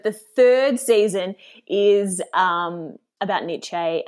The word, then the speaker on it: Nietzsche: Mussolini also studied some other philosophers, including, I hope I say this right, Nietzsche. – Yeah, Americans say that one way and we say it another. I would say Nietzsche.